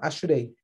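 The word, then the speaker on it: Ashrei (אשרי): A gente fala Ashrei, essa é a pronúncia, Ashrei.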